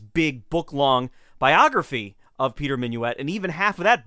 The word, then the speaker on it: Minuit: Minuet might have been the way people pronounced his name, seeing as how the Germans would like to pronounce every letter in a word, even to this day.